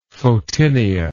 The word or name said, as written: photinia